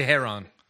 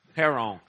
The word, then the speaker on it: heroin: Yeah, Mostly Heron. – Heron. – I think he just wants to say Heron. – That's how they say it, Heron.